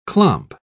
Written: clomp

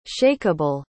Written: shakable

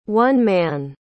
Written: one-man

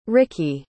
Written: rickie

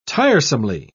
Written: tiresomely

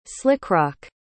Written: slickrock

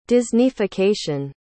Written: disneyfication

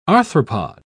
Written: arthropod

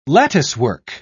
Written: latticework

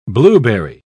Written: blueberry

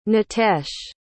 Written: nitesh

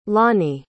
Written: loni

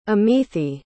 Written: amithi

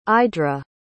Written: idra

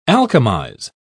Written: alchemize

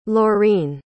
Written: laureen

